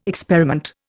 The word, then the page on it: experiment: Indian